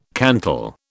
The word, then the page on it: cantal: British English